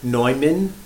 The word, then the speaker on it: Neumann: The native pronunciation of our saint's name is indeed Neumann,